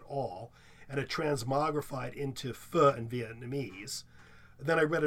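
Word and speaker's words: phở: It's spelled P-H-O, and it's actually pronounced pho.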